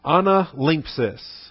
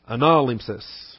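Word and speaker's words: Analimpsis (ἀνάλημψις): Analimpsis, with the accent on the ana. Analimpsis. Analimpsis.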